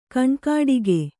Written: ಕಣ್ಕುಟಿಕೆ

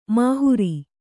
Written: ಮೊಹರೆ

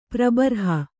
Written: ಪ್ರಬರ್ಹ

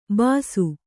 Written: ಬಸಿ

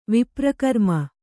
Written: ವಿಪ್ರಕರ್ಮ

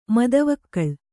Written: ಮದವಿಕಳ

